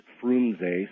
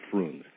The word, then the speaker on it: Frunze: Some people pronounce it Frunze. Some people just say Frunze. It's pronounced. It's spelled F-R-U-N-Z-E.